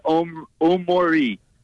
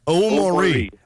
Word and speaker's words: Omori: And I know I said that wrong. Omorori, how do you say it? – Yeah, it's pretty funny, actually. In the game the other day, it was mispronounced like 12 different times, and actually one of the local beat writers wrote an article about how to properly pronounce his name today, but it's Omori. – Omori. Okay.